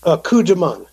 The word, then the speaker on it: Coup de Main: The album that is from is called, I hope I'm pronouncing this correctly, Coup de Main? Is that correct? – Coup de Main. – Coup de Main. – French for secret attack.